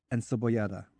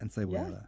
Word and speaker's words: encebollada: Carne frita, and let me see if I can pronounce this correctly. Encebollada. – Yes. Yes, Encebollada? – you said it right.